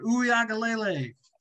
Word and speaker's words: Uiagalele: Uiagalele.